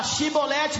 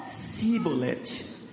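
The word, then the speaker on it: shibolete (שיבולת): Aí eles tinham que falar shibolete, mas eles falavam cibolete. Parece que tinha uma diferença na língua, não é? E eu vou explicar. Não conseguiam pronunciar.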